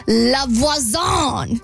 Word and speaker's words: La Voisin: So she meets this person named La Voisin. It's the correct pronunciation.